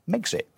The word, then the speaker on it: Megxit: as Megxit, which makes perfect sense. It rhymes with Brexit.